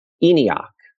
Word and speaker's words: ENIAC: But it is pronounced ENIAC.